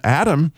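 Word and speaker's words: Adam (אדם): The word Adam, which, by the way, is the word for people in the Bible, in the Old Testament, Adam is the way you would pronounce people. Well, that ah sound is the letter Aleph, which means the father.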